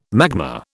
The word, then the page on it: magma: British English